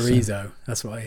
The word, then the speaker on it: chorizo: Let's go back to chorizo. How do you pronounce it? – Chorizo. – Like, you've got a lisp in the middle of that. Chorizo. – Well, basically, they said it's like a TH.